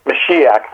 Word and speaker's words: Mashiach: The word translated Messiah, and I'm hoping I pronounced this word correctly. My Hebrew is not very good. Mashiach? Am I close? Mashiach, thank you.